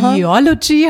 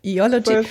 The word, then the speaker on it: Eology: Eology habe ich gelernt. Die Betonung liegt auf dem ersten O. Eology.